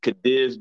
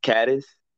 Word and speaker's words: Cadiz: No, actually, it's really, I pronounce it Cadiz, but it's really pronounced Cadiz.